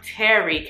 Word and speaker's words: tarry: And Tarry, not Terry like the name, but Tarry, can you tarry?